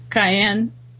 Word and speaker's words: Caen: I think it's Caen. And it's spelled C-A-E-N.